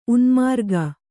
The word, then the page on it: ಉನ್ಮಾರ್ಗಿ: unmārgi; Kannada